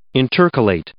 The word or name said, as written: intercalate